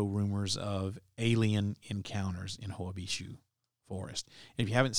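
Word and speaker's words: Hoia Baciu: Hoabishu. And it's spelled Hoabaciu, Hoabishu Forest.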